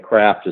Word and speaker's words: Craft: The easiest way to find me, instead of going to my web address, is Byron is spelled B-Y-R-O-N, and then Craft is just like Arts and Crafts, C-R-A-F-T.